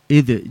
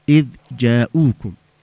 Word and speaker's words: إذ: كيف بتقولي أنت إذا جاءوكم؟ لا تقوليش إذا، لكن كيف ننطق النطق الصحيح؟ إذ جاءوكم، إذ جاءوكم، واضح؟ – أيوه.